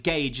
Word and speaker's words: Gauge: It means that I don't know how to pronounce certain words, and I always used to pronounce Gage Gorge, because the AU sound should make an OR sound linguistically, yet because English is a made-up language with five different languages stacked on top of each other, Gorge is not the correct pronunciation, and Gage is instead the correct pronunciation.